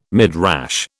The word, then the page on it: Midrash: British English